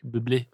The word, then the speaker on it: Bublé: Bublé, tror jeg, det er udtalt i virkeligheden. – Jeg elsker Jan D.D. – Det er fransk. Bublé.